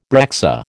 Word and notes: breccia: American English

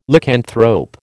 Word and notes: Lycanthrope: American English

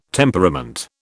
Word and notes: temperament: British English

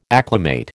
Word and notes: acclimate: American English

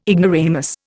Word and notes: ignoramus: Indian

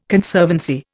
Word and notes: Conservancy: Indian

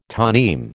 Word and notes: toneme: American English

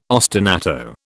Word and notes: ostinato: British English

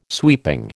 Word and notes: sweeping: American English

